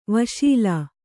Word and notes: ವಶೀಲಿ: vaśili

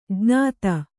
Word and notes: ಜ್ಞಾತ: jñāta

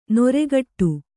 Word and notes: ನೊರೆಗಟ್ಟು: noregaṭṭu

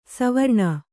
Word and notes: ಸುವರ್ಣ: Kannada

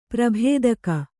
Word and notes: ಪ್ರಾಭಾತಿಕ: Kannada

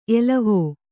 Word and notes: ಎಲುವು: kannada

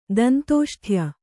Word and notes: ದಂತೋಷ್ಠ್ಯ: dantōṣṭhya

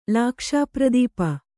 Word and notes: ಲಾಕ್ಷಾಪ್ರದೀಪ: Kannada

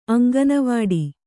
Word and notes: ಅಂಗನವಾಡಿ: aŋganavāḍi

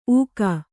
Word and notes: ಊಕ: kannada

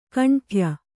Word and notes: ಖಂಡೆಯ: Kannada

